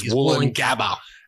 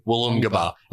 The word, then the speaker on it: Woolloongabba: They're not wrong. I think that's genuinely how it's pronounced. – I also think so. – As soon as he said it, I was like, I hate it and I love it. And that's exactly how it's supposed to be pronounced. There's no way that the indigenous word for that area is Woolloongabba. – There's no way. – Wollongabba. That's definitely how it's pronounced.